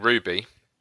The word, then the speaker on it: RWBY: Then we played a game which everyone seems to be pronouncing Ruby, even though it's spelled R-W-B-Y, but that's those crazy kids.